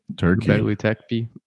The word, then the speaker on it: Göbekli Tepe: Göbekli Tepe.